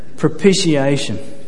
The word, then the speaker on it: propitiation: I love words ending in T-I-O-N. Propitiation. And Jody, you said it with confidence, therefore that's how it's pronounced.